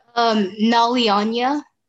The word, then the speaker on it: Nalyanya: Nalyanya.